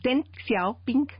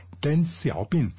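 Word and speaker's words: Deng Xiaoping: El libro rojo y además también se pronuncia Ten. – Ten, Ten. – Ten Xiaoping. – Ten Xiaoping.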